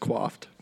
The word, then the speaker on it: coiffed: His coiffed beard, if I can say perfectly coiffed. – Believe it's pronounced quaffed, but go off.